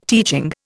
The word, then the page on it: teaching: Indian